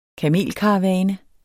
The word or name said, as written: kamelkaravane